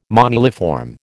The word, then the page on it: moniliform: American English